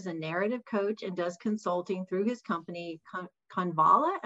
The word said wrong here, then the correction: Convalo: Well, that's Convalo, which is sort of a Latin word, which means to grow stronger.